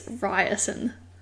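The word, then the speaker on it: Riorson: So I just said, and when I was reading it, I said Rawson. – I imagined Rawson. Is that the... – Okay. But, so in the audiobook, I've heard audio, it's Ryerson.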